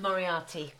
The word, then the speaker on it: Moriarty: Moriarty.